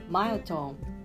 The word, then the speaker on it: myotome: 近分節は英語でマイトーンと発音します。